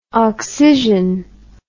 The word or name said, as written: occision